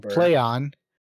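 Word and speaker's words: Plaion: It's called Playon, and when they announced it was called Playon, they're like, it sounds exactly how it's spelled.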